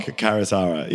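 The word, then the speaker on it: Karuizawa: Karuzawa, yeah. So that is how you pronounce it. You nailed it.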